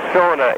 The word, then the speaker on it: Sona: By the way, that is, I think Sona is really the true correct pronunciation. However, on the old 78 records that I've got, they've got marked Sonic, S-O-N-I-C.